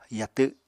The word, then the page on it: yatw: apatani